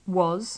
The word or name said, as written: was